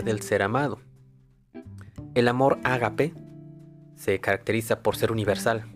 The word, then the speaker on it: ágape: Eh, y el último, es el ágape. Ágape con acento en la A, por si lo van a buscar, los griegos denominaron ágape al amor más puro e incondicional que existe.